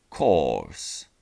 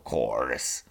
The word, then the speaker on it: course: ale zwróćmy uwagę na słówko kurs. Wymawiamy go course. Course. Po amerykańsku byłoby to course.